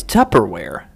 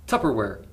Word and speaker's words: Tupperware: It's Tupperware. – How do you spell it? – I think T-U-P-P. I don't know. – Tupperware. – It's P, right? – T-U-P-P-E-R-W-A-R.